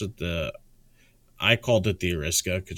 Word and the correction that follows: Arisaka: just because it was the Arisaka.